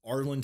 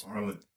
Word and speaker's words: Arlen: Give you the point since you wrote it down but mispronounced it. It is Arlen, Texas. – Arlen.